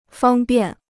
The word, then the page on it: 方便: fāng biàn